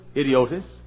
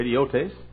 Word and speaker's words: idiotes: We'd pronounce it idiotis, or idiotes, which was a word that became a word that described a private kind of person.